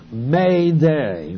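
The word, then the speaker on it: Mayday (μηδέ): M-E-D-E, where you have the word neither. And it pronounce Mayday, like someone's going down on the other side of the ship.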